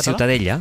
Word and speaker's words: Ciutadella: Ciutadella.